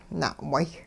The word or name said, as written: na'way